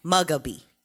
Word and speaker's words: Mugabe: First name is Robert, but his last name is Mugabe.